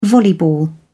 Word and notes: volleyball: British English

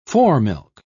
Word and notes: foremilk: British English

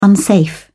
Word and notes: unsafe: British English

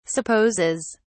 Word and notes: supposes: British English

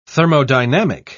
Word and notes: thermodynamic: British English

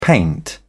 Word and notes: paint: British English

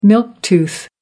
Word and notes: milk tooth: American English

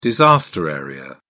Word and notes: disaster area: British English

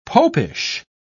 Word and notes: popish: British English

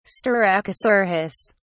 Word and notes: styracosaurus: British English

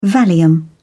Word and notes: valium: British English

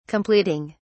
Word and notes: completing: British English

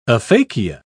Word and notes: aphakia: British English

